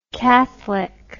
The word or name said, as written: catholicness